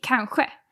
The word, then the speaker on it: kanske: But some people say kanske and have more of the sh sound.